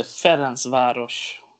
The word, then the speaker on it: Ferencváros: Hur ska det uttalas? – Då är det Ferencváros.